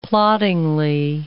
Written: ploddingly

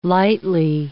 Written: lightly

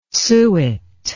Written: suet

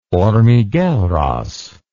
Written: Hormigueros

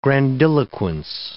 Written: grandiloquence

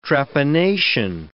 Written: trepanation

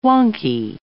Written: wonky

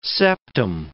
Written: septum

